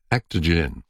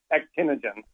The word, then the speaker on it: Actinogen: Joining me now is CEO of Actogen. You're going to have to tell me how to pronounce that. Actogen. Actinogen. – Actinogen.